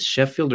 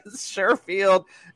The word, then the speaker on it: Sherfield: So let's try, is it Sheffield or Sherfield? Whoever that third guy is. I don't know his name. He's the guy that steals all Jalen Waddle's points from me. – Sherfield, indeed.